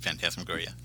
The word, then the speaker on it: Phantasmagoria: Number three is Phasmagoria. – Uh-huh, Phantasmagoria, yeah. – Yes, thank you. I can never say that right.